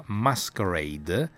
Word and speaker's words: MSQRD: Allora, l'app della settimana, cogliamo anche una notizia: Facebook, che ha un sacco di soldi, si è comprato un'app che si pronuncia Masquerade, anche se è scritta MSQRD, cioè hanno tolto le vocali, hanno tenuto Masquerade, e è un po' idiota, però ogni tanto va bene anche fare un po' di divertirsi.